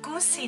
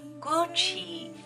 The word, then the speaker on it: Gucci: 哇，这个复古的包包是不是 Gucci 的呀？ No. The correct one is Gucci. Gucci. Ch.